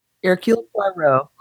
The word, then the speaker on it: Hercule Poirot: And it's a, you know, I can't pronounce things in French very well, but Hercule Poirot. Did I do okay? – Hercule Poirot.